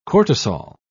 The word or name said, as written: cortisol